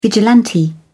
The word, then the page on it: vigilante: British English